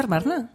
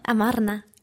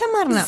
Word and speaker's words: Amarna: ¿He dicho Armarna? – Amarna. – ¿Ah, es Amarna? – Sí.